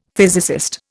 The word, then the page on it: physicist: Indian